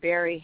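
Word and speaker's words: Barri: I do like I have a Facebook page, and if you put my name Barry Hanna in the Facebook,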